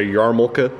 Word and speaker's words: yarmulke: I guess the original pronunciation is a yarmulke, but we say yarmulke in America.